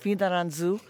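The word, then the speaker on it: Fianarantsoa: A difficult name to pronounce, I have to say, and it's not written like that, like a lot of Malagasy. But anyway, that's how it's pronounced, Finaranzu, and it's in the southern part of the central highlands, or the central plateau of Madagascar.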